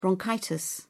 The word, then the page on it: bronchitis: British English